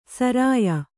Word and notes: ಸೂರ್ಯ: sūrya

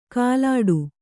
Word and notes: ಕಾಲಾಡು: kālāḍu

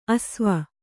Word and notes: ಆಶ್ವ: āsva

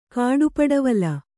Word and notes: ಕಾಡುಪಡವಲು: kāḍu padavala

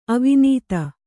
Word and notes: ಅವಿನೀತ: avinīta

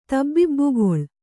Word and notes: ತಬ್ಬಿಬ್ಬುಗೊಳ್: Kannada

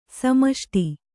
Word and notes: ಸಮಷ್ಟಿ: samaṣṭi

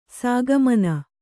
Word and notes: ಸಾಗಮನ: sāgamana

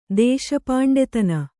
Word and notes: ದೇಶಪಾಂಡೆತನ: Kannada

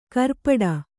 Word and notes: ಖುರಪುಟ: kannada

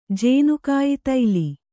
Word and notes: ಜೇನುಕಾಯಿತೈಲಿ: Kannada

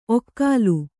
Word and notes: ಒಕಲು: okalu

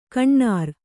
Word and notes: ಕೋನೇರಿ: konēri

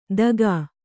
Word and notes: ದಗಾ: dagā

